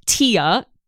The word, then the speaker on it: TEA: Yes, the organization is named TIA, T-E-A, but pronounced like Tia and Tamara Mowry. And the awards are the THEAs, T-H-E-A.